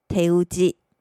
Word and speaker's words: 手打ち: 有时候乌龙面店或者是蕎麦面店会在门口写上“ 手打” 这两个汉字，日文念成“ 手打ち”。手打ち，手打ち，这代表该店所使用的面条都是手工制作而非机械制造，所以手工乌龙面就念成了“ 手打ちうどん”。